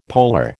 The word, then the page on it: polar: American English